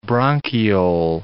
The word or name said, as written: bronchiole